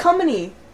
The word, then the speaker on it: Cumming: How am I saying it? Cumming. – It's one syllable.